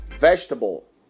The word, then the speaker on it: vegetable: Bom, a segunda palavra também, muito parecida também com o português, só que a segunda letra E, ela não é pronunciada, tá? Então vamos lá. Vegetable.